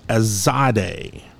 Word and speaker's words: tsade: The Hebrew letter assigned is called Tsade. It is T S A D E. It is pronounced T S as in nuts.